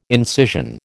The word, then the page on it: incision: American English